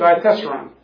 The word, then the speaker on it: Diatessaron: So Diatessaron.